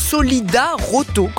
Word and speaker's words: Solidaroto: Donc Solidaroto, comme ça se prononce, Solidaroto, comme une auto.